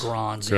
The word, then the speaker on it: Grans: I believe it's pronounced Helge Grans. – Granzi. Granzi. That- that's right.